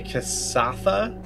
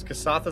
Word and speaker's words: Kasatha: Simion is a Kasatha bounty hunter. Kasatha, is that how you pronounce it? – That sounds right to me, sure.